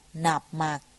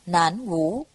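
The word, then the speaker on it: Nạp Mạc (南無): Hạnh là chí thành khẩn thiết, thường niệm Nam Mô, âm đọc là Nạp Mạc. Do chữ Nam Mô, nếu đọc theo giọng quan thoại thông thường, sẽ thành Nản Vũ.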